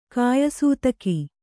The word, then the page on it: ಕಾಯಸೂತಕಿ: kāyasūtaki